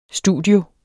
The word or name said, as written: studio